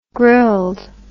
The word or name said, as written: grilled